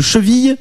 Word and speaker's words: cheville: Le son Y de cheville découle de l'évolution du latin clavicula.